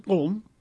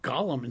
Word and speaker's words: Gollum: I'm talking about his other name, which should be pronounced Gulm. Mm-hmm. Because Tolkien describes it as a gulping sound from his throat that he became a thing when he was eating fish. Mm-hmm. So it's not Gollum, it's Gulm. Mm-hmm. Is the way to pronounce that.